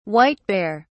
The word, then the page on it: white-bear: British English